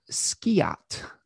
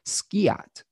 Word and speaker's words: Skiat: So on the first day today, spring equinox, the sun does make a conjunction with a fixed star called Skiat. That's the pronunciation I've settled on with that. Skiat, which is a fixed star in Pegasus at 0 degrees of Aries.